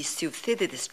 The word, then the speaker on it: Thucydides: The Thucydides trap. I hope I pronounced it well. – You pronounced it exactly right.